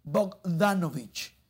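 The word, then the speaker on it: Bogdanović: Bernard Danovich, 18 puntos, 8 rebotes, 6 asistencias. Y por la causa perdedora, 20 puntos, 10 rebotes, 8 asistencias para Jimmy Butler. Lo dije bien, Bogdanovich.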